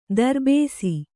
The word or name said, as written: ದರ್ಬೇಸಿ